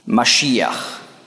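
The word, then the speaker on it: Mashiach (משיח): Die Griechen konnten Maschiach nicht aussprechen, weil auf Griechisch gibt es kein Sch-Laut.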